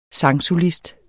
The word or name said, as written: SANGSOLIST